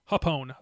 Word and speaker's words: Japón: The Chaos Los Ingobernables de Japón, or Japón? How do you pronounce it? – Japón. Los Ingobernables de Japón.